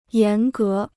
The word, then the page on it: 严格: yán gé